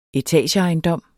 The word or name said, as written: etageejendom